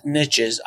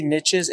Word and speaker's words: niche: Now, you talk also a lot about niches. I say niches, and I found out recently, according to the Webster's Dictionary, it is pronounced niche or niche, depending. So it, both are correct.